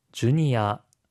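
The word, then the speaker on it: ジュニア: 주니아, 주니아. – 주니아, 주니어 청소년입니다. – 베비, 베비. – 베비, 베이비 아기인데요. 혹시 발음 팁 있을까요? – 시니아, 주니아와 같이 일본어의 외래어에서 어 발음은 보통 아로 발음되는 경우가 많습니다.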